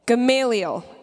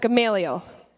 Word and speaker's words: Gamaliel: Gamaliel. Can you say that? Gamaliel.